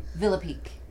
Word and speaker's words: Villepique: Gene, how do you say your last name? – Villepique. – It is okay, so I'm right. – But it's not the that's the Americanized pronunciation. – Is it one L or two? – That were It's two. – Okay. – And so it is Villa. So we are talking village.